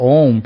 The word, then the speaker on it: Om: The simplest of mantras is the universal mantra Om, pronounced Ah, Ooh, Mm.